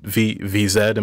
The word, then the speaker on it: VZ: The next song in here, there's actually a gang of people on the track, but VZ, am I saying that right? – Yeah.